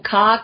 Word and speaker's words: ka: And so when you're cultivating your orgasm, you're actually strengthening your ka. You're strengthening that matrix of light. – Could you spell that? That are you saying ka, K-A? – Yeah.